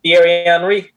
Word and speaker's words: Thierry Henry: Is that how I pronounce it? Thierry Henry. Henry. Yeah. Yes, sir.